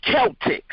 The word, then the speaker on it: Celtic: Well, it's spelled like that, C-E-L-T-I-C. But it's not the Boston Celtics. I mean, it's not the Celtics as in the Boston Celtics. There, it's pronounced the Celtic.